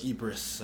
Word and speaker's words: Ypres: So how do you say that? – Woods of Ypres. – Woods of Ypres? – Ypres, uh-huh.